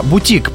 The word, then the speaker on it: бутик: На «и», а бутик производный, заимствованный из французского, ударение на последний слог «и» во всех формах этого слова.